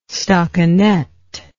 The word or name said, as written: stockinette